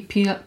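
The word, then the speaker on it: Peele: Mam tutaj na myśli i y, Astera i, i Pila, Pila też. Y, tak się wymawia jego nazwisko? Jordan Pil. Mhm. – Y, tak, tak, tak.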